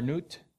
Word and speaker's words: Nut: I don't know if you pronounce it nut or newt, but it's N-U-T, so in English, it's nut.